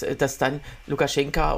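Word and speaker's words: Lukaschenka (Лукашэнка): Also du musst es, du musst es wie so eine Mischung aus O und A sprechen, so. Lukaschenka. – so, so, so wird es ausgesprochen.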